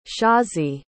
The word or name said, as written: shazi